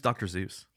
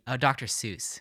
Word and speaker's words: Seuss: Who's Dr. Zeus? – Dr. Seuss.